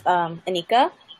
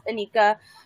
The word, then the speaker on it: Anekia: Hi, my name is Anika. My name is spelled A-N-E-K-I-A, but the I in my name is silent, so it is really pronounced Anika.